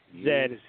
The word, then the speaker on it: Zed: I love how the Canadians say Zed. Mike L. always tries to quote on the show. – That's how you say it. – Yeah, that's how you say it. Zed. A to Zed.